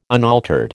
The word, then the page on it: unaltered: American English